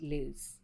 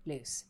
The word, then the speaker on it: Luz: So it's spelled L-U-Z. Nubile. Put it away, Jen Rogan. – Just saying. – Her name is spelled L-U-Z, but her Instagram informs me that it's pronounced Luce. Luce.